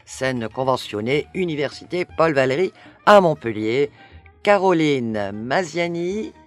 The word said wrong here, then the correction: Mazini: j'ai bien prononcé ? – Non, c'est Mazini. – Mazini, pardon, l'accompagne, elle sera au texte et à la dramaturgie de ce spectacle.